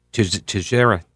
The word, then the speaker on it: Teixeira: Teixeira is how he pronounces that.